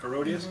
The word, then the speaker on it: Herodias: But when Herod's birthday was kept, the daughter of Herodias, am I saying that right? Mm-hmm. Herodias? Mm-hmm.